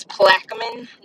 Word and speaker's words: Plaquemine: I would say I am listening to the Monique Kitts episode right now, and I love y'all, but y'all are butchering these names for Louisiana. It is Plaquemine, Louisiana, and it is McNeese University.